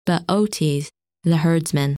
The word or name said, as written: Bootes